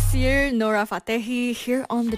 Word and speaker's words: Siir: That's one of the FIFA World Cup 2026 songs, and it's spelled S-I-I-R, Sir.